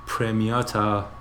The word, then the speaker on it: Premiata: Actually, I should pronounce it more Italian. Premiata for Neria Marconi, and from their wonderful 2017 released album Emotional Tattoos.